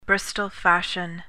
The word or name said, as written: bristol fashion